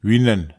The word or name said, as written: winen